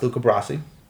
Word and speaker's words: Luca Brasi: Uh, one, I finally know how to pronounce Luca Brasi. Okay. It's not Lou. – Cabrasi. – Yeah. – It's Luca Brasi. Luca, yeah.